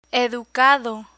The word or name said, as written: educado